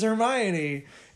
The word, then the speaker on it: Hermione: Like, oh, that's how you say her name is Hermione.